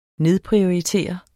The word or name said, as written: nedprioritere